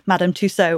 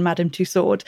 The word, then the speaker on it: Tussaud: She became the person in history known as Madame Tussaud, or the English pronunciation Madame Tussaud.